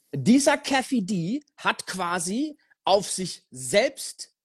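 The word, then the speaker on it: Keffe D: Da haben die einen Kerl namens Kefi D, glaube ich, spricht man den aus. K-E-F-F-E und dann D, Kefi D, festgenommen.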